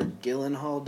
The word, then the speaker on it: Gyllenhaal: It's directed again by Christopher Nolan and stars Christian Bale, Michael Caine, Heath Ledger, Aaron Eckhart, Maggie Gyllenhaal, or Gyllenhaal, depending on who you are and how you like to pronounce things, Morgan Freeman, Gary Oldman.